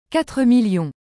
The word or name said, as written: 4000000